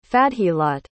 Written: Fadheelat

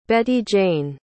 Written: Betty Jane

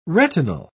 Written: retinal

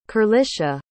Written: kerlisha

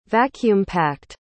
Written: vacuum-packed

